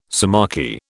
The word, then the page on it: sciamachy: British English